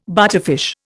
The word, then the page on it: butterfish: Indian